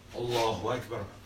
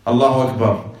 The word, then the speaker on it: Allahu Akbar: But before we proceed, let's talk about the pronunciation of the takbir. How do you say the takbir? – Allahu Akbar. – Allahu Akbar.